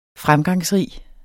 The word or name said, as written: fremgangsrig